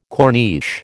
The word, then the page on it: corniche: American English